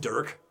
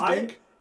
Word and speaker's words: Dink: Did you call him Dirk? – Yeah. – No, he's Dink. – It's an N. It's Dink, not an R. It's D-I-N-K. I have been reading D-I-R-K.